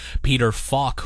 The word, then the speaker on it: Peter Falk: Peter Falk, who you remember as Columbo, F-A-L-K.